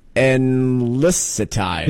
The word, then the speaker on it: Enlicitide: Oh, well, that's Enlicitide. – Yeah, very nice. Yeah, yeah, not too shabby. – I was going to give it an Enlicitide, a hard C, but I went with the soft.